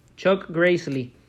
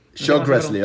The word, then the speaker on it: Chuck Grassley: Chuck Grassley. – Chuck Grassley, ok, perfecto.